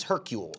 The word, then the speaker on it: Hercule: Well, no, because his name is Hercule. Oh. But it's pronounced Hercule. But people are like, hey, Hercule, you're Hercules.